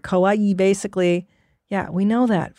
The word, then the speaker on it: kawaii: It's not kawaii. It's kawaii. Say it right.